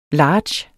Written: large

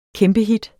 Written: kæmpehit

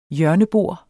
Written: hjørnebord

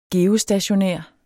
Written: geostationær

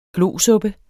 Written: glosuppe

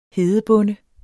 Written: hedebonde

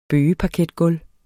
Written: bøgeparketgulv

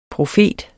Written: profet

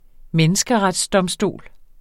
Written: menneskeretsdomstol

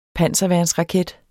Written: panserværnsraket

